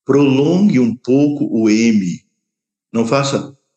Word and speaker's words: Om: Lembre-se, sempre que você pronuncia a letra M, a boca fecha no final. Om, Ram, Lam, Sham.